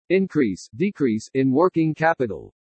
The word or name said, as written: INCREASE DECREASE IN WORKING CAPITAL